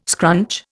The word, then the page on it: scrunch: Indian